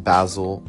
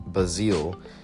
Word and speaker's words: basil: Apparently it's not pronounced Basil or Basil, it's pronounced Basile. And this is news to me too. I mean, my friend told me yesterday, and he's somewhat of a language expert he knows like five different languages and he says it the word originates in Sweden and that there they pronounce it Basile.